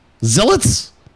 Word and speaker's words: zealots: Zealots? – That, are you serious? – How do you say it? – No, you're good. Zealots was really good.